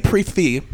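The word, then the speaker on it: prix fixe: Have you guys ever heard of people calling it a pre-fee instead of a prefix? – No, I would laugh in their face. – I once heard someone say that, and I was like, hold on, am I saying this wrong? And I was like, I was very surprised. – I believe it's correct. – It is correct, but come on. – It's pre-fee.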